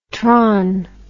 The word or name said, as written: tron